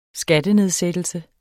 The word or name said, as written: skattenedsættelse